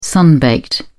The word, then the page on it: sun baked: British English